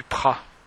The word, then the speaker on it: Praz: Du coup, on s'est retrouvé au-dessus du Prat. Le Prat, qui s'écrit le Praz, c'est une station, une petite station, mais plus grande que Latania, qui est à l'ouest de Latania.